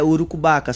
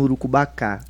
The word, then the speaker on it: Urucubaca: É Urucubaca, só que aí como na pronúncia dentro da música, sim. – Acabou pronunciando Urucubacá.